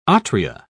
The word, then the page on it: atria: British English